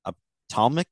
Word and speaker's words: ophthalmic: You're an ophthalmic photographer.